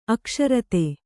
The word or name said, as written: ಅಕ್ಷರತೆ